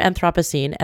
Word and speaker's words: Anthropocene: Beautifully shot, and the term Anthropocene, and I absolutely stand by the fact that I'm pronouncing it right.